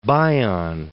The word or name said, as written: Bion